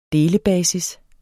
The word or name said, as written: delebasis